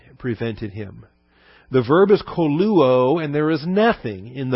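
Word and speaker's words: koluo (κωλύω): The verb is koluo. K-O-L-U-O. They're both omegas there, long O's. Kappa, omega, lambda, upsilon, omega.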